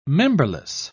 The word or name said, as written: memberless